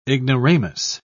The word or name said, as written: ignoramus